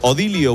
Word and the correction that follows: Odilo: buenos días. – Buenos días, Odilo, es Odilo. – Odilo, es que es la primera vez que lo pronuncio, pero me encanta.